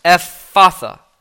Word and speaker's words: Ephphatha: Actually, the PH, the second PH is silent. It's Ephphatha. Ephphatha is how you pronounce it.